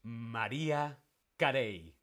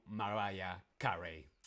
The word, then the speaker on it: Mariah Carey: En España decimos María Carey, no Mariah Carey.